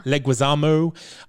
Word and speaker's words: Leguizamo: Now, he was played by John Leguizamo. Is that how we pronounce his name? I never... – Leguizamo.